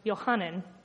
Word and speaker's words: Yohanan: The name John in Hebrew is pronounced Yohanan. It comes from the roots Yo, referring to the name for God, Yahweh, and Hanan, meaning to be gracious.